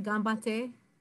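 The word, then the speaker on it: Ganbatte (頑張って): Ganbatte. Did I say that right? Ganbatte. – Perfect.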